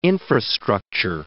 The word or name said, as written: infrastructure